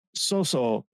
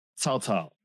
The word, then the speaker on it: Cao Cao: And the thing is that Soso in Japanese is the Japanese pronunciation of Cao Cao.